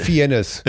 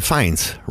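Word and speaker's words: Fiennes: Wird, das, wird der Feins ausgesprochen? – Oder äh, Fienes? – Äh, Feins, Ralph Feins.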